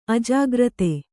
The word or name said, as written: ಅಜಾಗ್ರತೆ